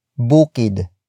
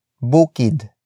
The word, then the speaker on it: bukid: And that is bukid. Bukid. Bukid. Bukid is spelled as B-U-K-I-D.